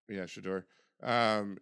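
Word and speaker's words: Shedeur: And then obviously his son Shadur, how do you say his name? – I think it's Shadur. – Shadur.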